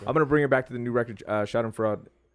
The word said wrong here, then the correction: Schadenfreude: I'm sure I'm saying that wrong, but every time. – It's Freud. – Shot and Freud. Schadenfreude, I'm terrible at that.